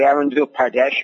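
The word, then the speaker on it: Arunachal Pradesh: especially Arundhati Pradesh, if I pronounced that correctly.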